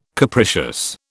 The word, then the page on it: capricious: British English